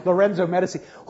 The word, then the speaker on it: Lorenzo Medici: We're told it's pronounced that way because the other way sounds too European. Lorenzo Medici, who is some nephew of another studio executive who's sitting there saying, yeah, I don't know why I'm doing here.